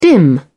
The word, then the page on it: dim: British English